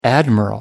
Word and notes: admiral: American English

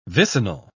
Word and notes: vicinal: British English

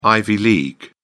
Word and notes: ivy league: British English

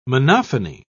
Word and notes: monophony: British English

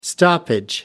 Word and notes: stoppage: American English

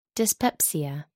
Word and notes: dyspepsia: American English

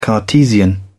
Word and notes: cartesian: British English

